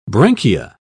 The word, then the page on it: branchia: British English